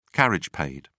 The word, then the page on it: carriage paid: British English